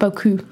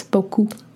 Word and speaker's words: beaucoup: Well, no, it's just she said, like, you can't let your tongue touch your teeth. So it's not beaucoup, it's beaucoup.